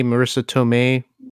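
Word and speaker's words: Marisa Tomei: Speaking of other Spider-Man stuff, apparently, who's the Aunt May, Marissa Tomei, right? Is that how you say her name? – Yeah.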